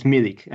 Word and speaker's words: Milik: So it's Milik, and the first name is Arkadiusz. Arkadiusz Milik.